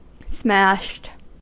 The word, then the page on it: smashed: American English